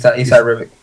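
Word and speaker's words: Esad Ribić: And we have a beautiful cover here by Isad Rebic. I don't know how to pronounce that. Do you know how to pronounce that? – Yeah, that's pretty much how it is. Isad Rebic.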